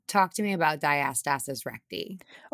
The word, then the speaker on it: diastasis: Did I say that correctly? – Honestly, I've only heard a few people say it. I say diastasis. – Diastasis. All right. Well, 10 points for the non-pregnant human over here.